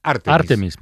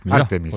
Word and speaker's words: Artemis: Bueno, la duda se ha extendido y la Fundeu estos días ha aclarado que la pronunciación correcta es la esdrújula. O sea, es Artemis. – Artemis.